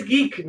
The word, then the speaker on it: geek: Antes que nada, vamos a, a ver qué es geek, ¿no? Y geek es pronunciado como wick.